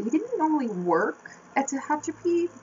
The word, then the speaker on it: Tehachapi: At least that's how they pronounced it in the episode. Tehachapi is how they pronounced it, so.